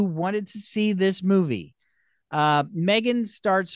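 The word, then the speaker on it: M3GAN: And I said, I guess the sequel will be called Mithrigan, but no, it's Megan, but it's a three. It's pronounced Megan.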